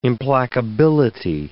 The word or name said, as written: implacability